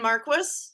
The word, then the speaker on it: Marquis: It's Anne Marquis, M-A-R-Q-U-I-S. If you call me Anne Marquis, I will answer to it, but that's not how I pronounce my name.